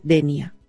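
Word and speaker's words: Dénia: DENIA. ¿Lo he dicho bien, Pep? – Sí, muy bien, muy bien.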